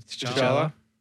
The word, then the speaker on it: T'Challa: But T'Challa, I think that's how you say his name. – T'Challa.